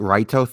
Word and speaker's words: Raito: The whole Light Raito thing. Yes, that's the pronunciation, but the authors always intended the word to be the English word light. So it's just one of the things that they can't say light with the language, but it is light in both languages.